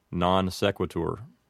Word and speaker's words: non sequitur: Our next expression is non sequitur. If you wanted to apply all the rules of classical Latin pronunciation, you would make the O in non a long O, and it would say non. And you also might make the E in sequitur something like sequitur. But that's not the way we typically pronounce it. Typically, with an Americanized pronunciation, we would just say non sequitur.